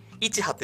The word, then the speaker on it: 一葉: で、うん、今これね、一葉って読むんですけど、これ一葉って読むんですよ。